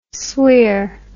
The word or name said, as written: sweir